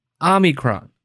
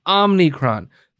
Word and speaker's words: Omicron: It's Omicron. That is the actual way to pronounce it. Omicron. Omicron. Omicron. It's not Omicron. There's no extra N.